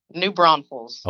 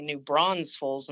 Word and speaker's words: New Braunfels: New Braunfels. – Okay, so it's like it looks. – Yes, except a lot of people like to say New Braunfels and throw that extra S in there.